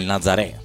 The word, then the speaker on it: Nazaré: lui si è spostato nel Nazareth d'Amata, nel Nazaré, probabilmente la pronuncia esatta, d'Amata, e ha formato i Fuloresta do Samba.